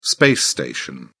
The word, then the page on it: space station: British English